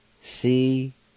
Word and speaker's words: Sequoia: Number two, Sequoia, and it's pronounced like this. Si-quo-yo-e. Si-quo-yo-e.